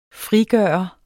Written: frigøre

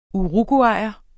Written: uruguayer

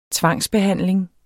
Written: tvangsbehandling

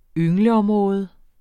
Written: yngleområde

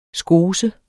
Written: skose

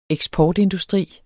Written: eksportindustri

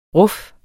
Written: ruf

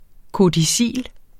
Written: KODICIL

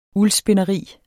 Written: uldspinderi

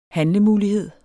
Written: handlemulighed

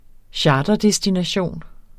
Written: charterdestination

